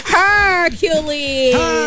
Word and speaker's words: Hercules: Tapos all caps yung her. Hercules. – Stress on the her.